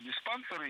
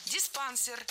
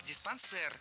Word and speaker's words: диспансер: У нас такая традиция в усадьбах было устраивать туберкулезные диспансеры и психические, неврологические всякие. – Диспансер. Неверно. Это тоже французское слово, и ударение в нем тоже падает на последний слог. Диспансер. Кстати, произносим с «э». Диспансер.